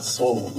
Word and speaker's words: Sol: So soul, when you hear it, the first thing that you think of is your soul, your hearts and your minds. But when you see it, it's spelled S-O-L and it's the power of the sun.